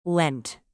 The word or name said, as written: lent